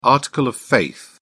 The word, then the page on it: article of faith: British English